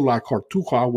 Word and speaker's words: La Cartuja: Now, the game on Sunday, the Supercopa España, as it's called, it's going to be played at Estadio La Corbetura, which is at Sevilla, okay? Cartuja, I should say. Excuse me, I want to pronounce that right. Estadio La Cartuja, which is in Sevilla.